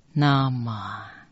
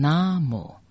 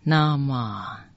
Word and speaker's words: 南无: 大悲咒只有 84 句，先看第一句，南无这两个字念成 Nama，下面还有这两个字念成 Namo，可是第一句念成 Nama，第三个字就念成呵，呵，像英文呵这样的音。